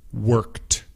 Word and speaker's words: worked: Worked. Same thing. It has the ED, but it sounds like one syllable. No es worked. Es worked. Worked. W-O-R-K-E-D.